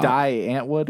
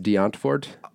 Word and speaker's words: Die Antwoord: Is it like Die Antwoord or something? – No. You know that's actually pronounced D'Antfort? – I'm sorry, what?